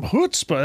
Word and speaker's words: chutzpah: Right now, you got to get yourself some chutzpah.